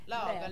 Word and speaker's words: Lagerlöf: Lagerløft. – Lagerløft. – Lagerløft. – Ja. Lagerlöf. – Lagerlöf, hvis man er svensker.